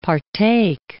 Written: partake

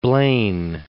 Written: Blaine

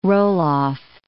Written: roll-off

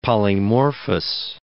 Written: polymorphous

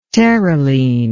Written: terylene

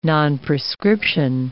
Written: nonprescription

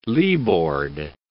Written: leeboard

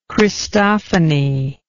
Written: Christophany

